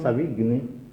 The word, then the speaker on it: Savigny: La vecchia tesi enunciata da Alphonse Savigny, si pronuncia così? Sì, ma è un astanziano, qualcuno pronuncia la tedesca.